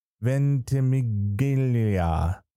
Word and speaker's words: Ventimiglia: Brown for This Is Us, Kit Harington, Game of Thrones, Bob Odenkirk, Better Call Saul, Billy Porter for Pose, and Milo Ventimiglia, This Is Us.